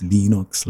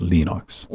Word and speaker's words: Linux: My name is Linus Torvalds, and I pronounce Linux. – Linux.